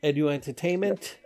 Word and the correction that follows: Edutainment: The Edutainment joint.